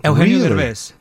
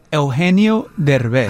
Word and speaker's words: Eugenio Derbez: Eugenio Derbez. – What? Say it again. I'm sorry. – Eugenio Derbez.